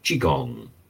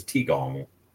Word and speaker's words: Qigong: And Anthony is saying it's spelled Qigong, Tigong, Qigong, Qigong. I was convinced it was Tigong.